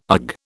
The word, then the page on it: ugh: British English